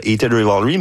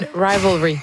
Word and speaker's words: Heated Rivalry: Et évidemment, on a beaucoup parlé dans la dernière année grâce à la trame sonore de Heated Rivalry, mais... Je l'ai bien prononcé, je pense, c'est la première fois. – Non, Heated Rivalry.